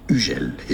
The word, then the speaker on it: Hugel: Hugel is the French pronunciation. U, that's the sound of the letter U. U, very French. The H is silent. And then Gel, not Hugel.